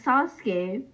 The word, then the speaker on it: Sasuke: Yeah, Sasuke.